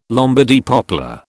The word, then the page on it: Lombardy poplar: British English